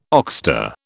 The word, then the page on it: oxter: British English